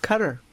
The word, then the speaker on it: Qatar: Qatar, it's pronounced.